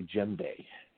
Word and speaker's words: djembe: The type of drum that I use is a West African drum called a djembe, and it's spelled D-J-E-M-B-E.